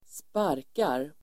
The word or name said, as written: sparkar